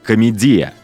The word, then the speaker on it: комедия: Кстати, Данте называл ее «комедия» с ударением на «и», на греческий манер.